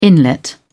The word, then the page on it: inlet: British English